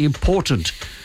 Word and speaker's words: important: Say important. It's a T in the middle. Say it properly.